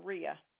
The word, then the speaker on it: Hreha: H-R-E. H-A. – I have H-R-E. H-A. – Yep. – How do you pronounce that one? – Rhea. – So the first H is silent? – Yeah. – Okay. So I have H-R-E-H-A.